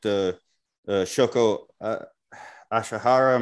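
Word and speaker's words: Shoko Asahara: In listening to a lot of what you've spoken about with Shoko Ashihara, am I saying that right? – Yes.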